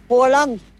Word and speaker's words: Haaland: Es con doble, como una doble O, ¿no? – Haaland.